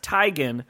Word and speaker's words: Teigen: And Chrissy Teigen responded, and she's like, yeah, it's kind of like how my name is pronounced Teigen,